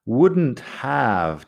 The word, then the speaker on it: wouldn't have: Wouldn't have también se puede acortar. Acaba siendo wouldn't have. Pronunciamos la T y la V y nada más. La L de would nunca se pronuncia.